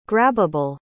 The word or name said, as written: grabbable